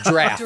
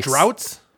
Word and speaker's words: draughts: No. Like drafts. – Giraffes. – Oh, draft. Okay. – Like drafts. Like, so it's spelled D-R-A-U-G-H-T-S. But like... – Droughts? – No. – Wait, how? – Oh. – D-R-A-U-G-H-T-S.